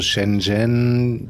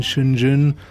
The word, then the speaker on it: Shenzhen: Spricht man das so aus? – Ja, ich sage mal so Shenzhen. Die Chinesen, die nuscheln das, glaube ich, so ein bisschen Shenzhen. Hm, ist okay, wie du es sagst.